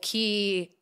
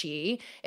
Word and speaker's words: Qi: Like, if for those of you who don't know, Key is actually pronounced Chi. It's a Mandarin word.